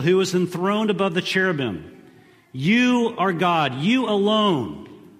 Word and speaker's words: cherubim: The correct pronunciation of cherubim, I think, you can ask Tracy Emery expert on pronunciation of biblical words, is cherubim. But I like to think of it as cheerubim.